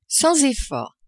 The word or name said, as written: sans effort